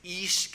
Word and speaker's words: ish: The derivation of that, by the way, in the word, in the Hebrew language, the word for man is ish, kind of spelled like you could transliterate it as I-S-H, and women transliterate it would be Isha, I-S-H-A, is how it would look in English if you took the Hebrew letters and transliterated those.